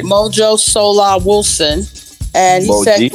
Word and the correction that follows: Moji: It's pronounced Moji. – Moji Solar Wilson.